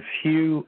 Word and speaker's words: Hugh: My name is Hugh, H-U-G-H, last name Trawlson is spelled T like Tom, then R-A-U-L-S like Sam, E like Edward, N like Nancy.